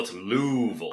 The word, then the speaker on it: Louisville: Not Louisville, it's Louisville.